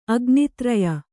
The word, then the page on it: ಅಗ್ನಿತ್ರಯ: agnitraya